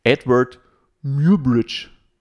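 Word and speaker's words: Eadweard Muybridge: den spreche ich nicht richtig aus, aber Edward Muirbridge. Wie würdest du das aussprechen hier, Janni, unser Englischexperte? experte ja, genau so. – Mir genau so, genau.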